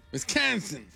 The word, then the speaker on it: Wisconsin: Wisconsin. Wisconsin. – Wisconsin. – Sí. Wisconsin.